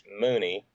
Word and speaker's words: Mooney: Alex Mooney, rhymes with Looney, uh, and he's he's had a couple of terms in Congress.